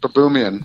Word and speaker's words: Baboumian: Baboumian. – Patrick Baboumian. – Baboumian.